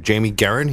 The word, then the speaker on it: Jamie Guerin: We have Jamie Guerin here.